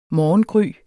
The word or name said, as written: morgengry